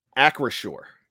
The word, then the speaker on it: Acrisure: Make sure I'm correct. Acrisure. – As far as I know, that's the right pronunciation. – Akrishwar Stadium.